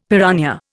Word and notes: piranha: Indian

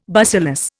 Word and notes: bacillus: Indian

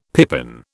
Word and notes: pippin: British English

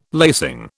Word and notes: lacing: British English

